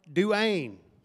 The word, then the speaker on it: Duane: Now if it were spelled like, I don't know, Dwayne Wade, it'd be like, oh, yeah, that's my boy. We got the same name. And my name is spelled Duane. D-U-A-N-E. Duane. Christopher Duane Reed.